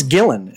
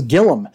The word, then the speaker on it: Gillan: Is it Gillan or Gillum? – I think it's Gillan. Is it not Gillen? – I don't know. – It's Gillen. Okay, it's Gillen. I thought it was Gillum.